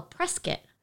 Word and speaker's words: Prescott: We were able to escape Scottsdale during this journey and we journeyed up near what is spelled as Prescott, but the locals call Prescott. – Which makes me think of like press kit, like the things they give us with all the details on the vehicles.